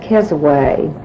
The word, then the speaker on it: Keswick: One thing of interest that I have found is that the older people pronounced that Kesaway, and I have wondered if it is of a word of Indian origin that perhaps they pronounce with the Indian correct pronunciation, whereas we use the American pronunciation.